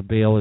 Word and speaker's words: Baal: You see, after Elijah in the Old Testament, Book of Kings, 1 Kings, had his great victory against the prophets of Baal, or Baal as some would pronounce it, in 1 Kings 18, Queen Jezebel said she wanted his head, and that scared Elijah out of his wits.